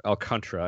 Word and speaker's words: Alcantara: We'll start off with Sandy Alcantara, or Alcantara, I suppose, as it's supposed to be pronounced, down at 100.